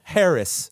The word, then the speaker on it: Haracz: You could find me by my name, Mike Harris, and it's spelled just like it sounds, H-A-R-A-C-Z. Harris.